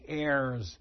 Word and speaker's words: heir: In verse 17, he described us as the heirs of promise. What's an heir? You know what an heir is? It's spelled with an H, but don't say hair. That's what's on your head.